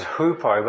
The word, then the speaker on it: hupo: But actually, the Greek word we pronounce is hupo, but it's the same word.、 Really.